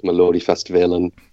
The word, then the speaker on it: Melodifestivalen: And then I think like it was maybe 2007 that I really got into it, and I discovered Melodifestivalen, and I probably pronounced that wrong, did I? – Well, it was close enough.